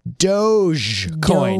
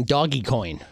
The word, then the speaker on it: Dogecoin: A lot of people were saying doggy coin. – Yeah, doggy coin. – Because it is spelled dog e coin. So I always thought it was Dodge coin, like the car. Dodge coin. – No, Doge. Yeah, Doge? You're missing a D there.